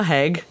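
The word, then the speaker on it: Hague: Four of their top tens on the Hot 100, including West End Girls, were produced by a gentleman named Stephen Haig. That's H-A-G-U-E. I hope I'm pronouncing his last name correctly. – That's how you say the Haig.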